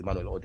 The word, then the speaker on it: Immanuel: My name again is Immanuel Odeyemi, and you can follow this conversation.